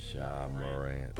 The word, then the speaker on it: Ja Morant: Ja Morant.